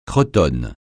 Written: cretonne

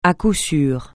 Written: à coup sûr

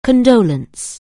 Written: condolence

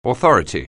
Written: authority

